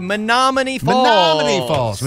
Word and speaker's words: Menominee Falls: That would be Menominee Falls.